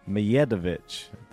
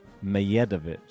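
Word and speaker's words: Medjedović: We have Arthur Fee, Ben Shelton, Luca Van Ascher, and Hamad, and this is the right pronunciation because I saw some people in the comments, Mijedovic. The D is silent like Djokovic. – How'd you say it? – Mijedovic. – Mijedovic.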